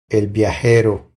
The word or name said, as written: El viajero